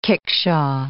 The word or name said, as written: kickshaw